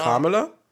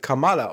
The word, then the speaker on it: Kamala: Äh, ja, weil, weil ähm, die amerikanische Vizepräsidentin heißt äh, Kamala Harris. Da ist die Betonung auf dem ersten A, also Aha. Kamala. – Und die Figur in der Miss Marvel-Serie wird Kamala ausgesprochen.